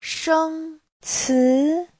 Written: sheng1ci2

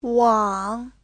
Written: wang3

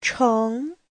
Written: cheng2

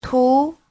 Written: tu2

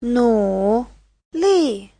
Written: nu3li4

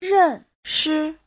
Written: ren4shi5

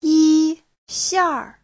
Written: yi1xiar4

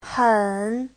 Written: hen3

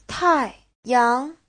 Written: tai4yang2

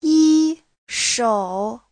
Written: yi1shou3

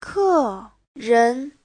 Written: ke4ren2

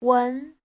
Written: wen2

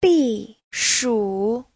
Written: bi4shu3

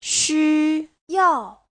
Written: xu1yao4

